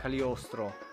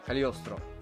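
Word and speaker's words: Cagliostro: Cali... Cali... Mm, cum e? Cali... Caligliostrono Shiro. Just nici, niciodată n-am știut să îi, uh, să-l pronunț așa, Lupin the Third, The Castle of Caligostro, uh, Caliostro, așa, Caliostro, bine, bine am zis, care e obviously scris, regizat de Hayao Miyazaki.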